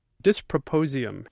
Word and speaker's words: dysprosium: It's properly pronounced dysprosium, D-Y-S-P-R-O-S-I-U-M.